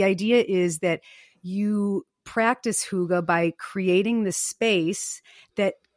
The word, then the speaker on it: hygge: I think the Danes would appreciate a pronunciation of hygge or hygge.